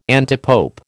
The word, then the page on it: antipope: American English